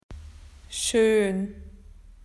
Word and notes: beautiful: Australian English

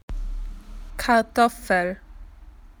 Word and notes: potatoes: Australian English

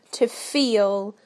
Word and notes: to feel: British English

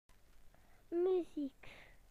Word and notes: music: British English

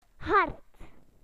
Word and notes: hard: British English

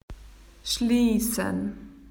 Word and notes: closed: Australian English

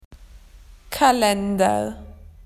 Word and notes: calendars: Australian English

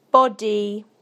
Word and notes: body: British English

